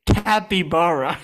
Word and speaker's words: capybara: How do you say it? – Capybara. – Capybara.